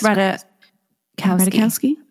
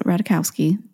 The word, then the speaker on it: Ratajkowski: I think it's Ratajkowski. I don't think you say the J. – Okay, so Ratajkowski.